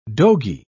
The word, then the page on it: dogy: British English